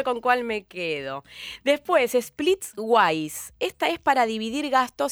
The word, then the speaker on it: Splitwise: W-I-S-E, Wise, Splitwise.